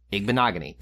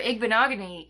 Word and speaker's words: Igbinoghene: Ig bina gene. Igbinoghene. to be fair, igbenogany is phonetically correct too.